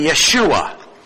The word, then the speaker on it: Yeshua: The word Jesus is a transliteration in the Greek of a Hebrew name, meaning Yahweh saves, and the pronunciation of Jesus is actually Yeshua.